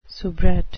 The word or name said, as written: soubrette